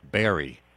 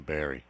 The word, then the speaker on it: Behre: His name is William Berry, and that's spelled B-E-H-R-E, William Berry, Dr. William Berry.